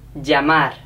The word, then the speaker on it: llamar: Llamar. Llamar. Llamar. – Llamar. – And okay. Remember to pronounce the R at the end.